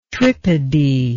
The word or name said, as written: tripody